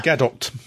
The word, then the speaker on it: Gadot: Gadot. – She actually pronounced it Gadot.